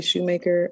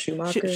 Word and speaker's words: Schumacher: So I'm familiar with, some people say Shoemaker. I think I'm one of those people. I think it's pronounced Schumacher.